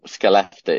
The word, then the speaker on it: Skellefteå: It's called Skellefteå is how we'd pronounce it in English. The Swedish is very, very different.